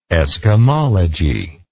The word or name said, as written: eskimology